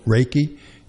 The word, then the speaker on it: Reiki: Reiki.